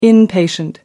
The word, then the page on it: inpatient: American English